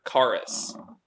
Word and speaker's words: charis: And in the New Testament, it's a word charis.